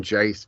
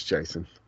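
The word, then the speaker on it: Jacen: I feel like Jace would be, or Jason would be a little, I keep saying Jace because. – Oh, I thought his name was Jace. – No, it's Jason. Jason. It's spelled like J-A-C-E-N, I think.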